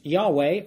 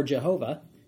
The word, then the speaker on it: Yahweh: Yod He Vav He, or sometimes pronounced Yahweh or Jehovah,